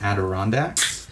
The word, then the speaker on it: Adirondacks: So the last story I have is in the Adirondacks. Did I say that right? – Sounded right.